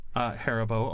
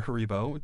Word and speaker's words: Haribo: Haribo or Haribo, depending on your pronunciation.